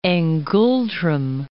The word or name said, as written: ngultrum